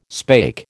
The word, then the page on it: spake: American English